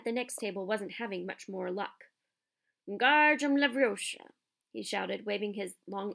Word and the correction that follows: Wingardium Leviosa: "You're saying it wrong," Harry heard Hermione snap. "It's Wingardium Leviosa. Make the gar nice and long."